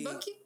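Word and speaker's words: Bauky: Buki Coffee. – Buki Coffee en Instagram, arroba Buki Coffee.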